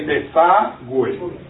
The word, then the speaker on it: desagüe: But you notice the U, that it has those tilde right on top, those two dots? Okay? So that's very important in order for us to pronounce that. Alright? So we say desagüe.